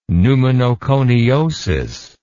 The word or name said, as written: pneumonoconiosis